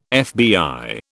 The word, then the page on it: FBI: British English